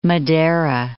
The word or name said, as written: Madera